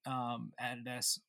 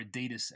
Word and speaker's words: Adidas: because obviously Adidas or Adidas, as the North Americans say, you know, putting stripes over things.